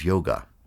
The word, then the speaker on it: yoga: Yoga, when pronounced in India, the final A is usually dropped, and they pronounce it as yog.